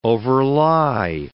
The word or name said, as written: overlie